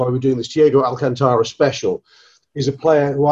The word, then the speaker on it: Alcantara: Thiago Alcantara, is that how you say it, Ian Ryan? – Yeah.